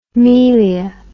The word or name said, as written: melia